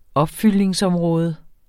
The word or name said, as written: opfyldning